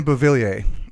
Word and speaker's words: Beauvillier: Beauvillier. – I got it. – Okay, I got it up. All it's right. right here. – That's right. – Yes, Anthony Beauvillier.